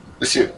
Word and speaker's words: Lisieux: Lisieux.